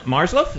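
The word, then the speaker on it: Marzluff: And his co-author, of course, it's John Marsloff. Is that how you say it, Tony? – That's correct.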